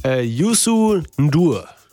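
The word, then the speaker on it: Youssou N'Dour: Willst du es mal probieren? – Ja, Yusu Ndur. – Ja, gar nicht mal so schlecht.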